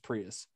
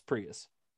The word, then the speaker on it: Prius: It's pronounced Prius. It's Prius.